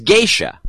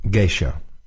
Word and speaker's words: geisha: Uh, if asked, she would demurely point out that a more correct pronunciation of geisha is geisha. Geisha. Not as one hears these days, geisha.